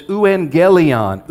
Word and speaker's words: euangelion: The Greek word is euangelion, euangelion. And it literally means good news. If you see it written out, you'll see the word angel in the middle of that, euangelion. That's a hard G in the Greek.